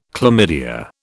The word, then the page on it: chlamydia: British English